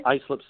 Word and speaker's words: Islip: You had the one, I'm not sure if I'm pronouncing it right, Islip Speedway? – Islip Speedway, yes.